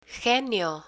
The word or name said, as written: genio